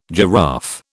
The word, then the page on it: giraffe: British English